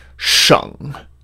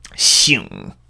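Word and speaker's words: sheng (省): So there's a character that is normally pronounced sheng, and it means like a province, in China or maybe in Canada or something, sheng. And sometimes, though, it is pronounced xing. And the first time I ever saw it pronounced xing was when I saw it in the word fan xing.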